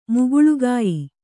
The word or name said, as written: ಮುಗುಳ್ಗಾಯ್